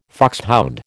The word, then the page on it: foxhound: American English